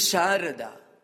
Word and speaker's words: Sharada: The name of mother frequently used is Sharada. And this often is spelled as S-A-R-A-D-A, one way of spelling. The other spelling is S-H-A-R-A-D-A. So, Sarada and Sharada, these are two words.